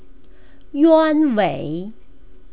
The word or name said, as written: yuanwei